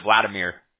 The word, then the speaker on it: Vladimir: And then Vlad, is it Vlad or is it Vladimir? – Full name Vladimir. – Okay. Is that V-L-A-D-I-M-I-R?